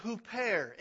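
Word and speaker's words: huper (ὑπέρ): In the original language, it is the preposition huper, H-U-P-E-R, if you wanted to spell it in English letters, huper.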